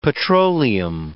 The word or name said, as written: petroleum